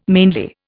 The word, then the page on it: mainly: Indian